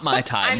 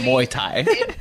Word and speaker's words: Muay Thai: Did I say that right? – Yeah. Not my Thai, Muay Thai.